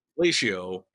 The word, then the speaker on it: Latio: It's ratio with an L. Latio. But where did that name come from? – I know it's a bad name because no one has said it as Latio the correctly the first time.